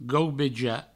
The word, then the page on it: gaubejar: gaubeja